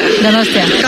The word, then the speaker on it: Donostia: Donostia. – Gosh. – Donostia.